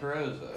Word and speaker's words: Carrozza: And then after that, it was Mike Carosa, who is, hope I say his name right, Mike Carosa. – Yeah, Mike Carosa. – Who is he on Twitter? – He's Mike Carosa, at Mike Carosa, C-A-R-R-O-Z-Z-A.